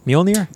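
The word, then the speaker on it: Mjölnir: How do you say Nomir? That fucking Mjolnir? Damn it, Mjolnir.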